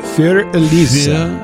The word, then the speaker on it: Für Elise: Is that how you pronounce that? Für? – Für Elise. Für Elise. – Für Elise. – The umlaut over the U.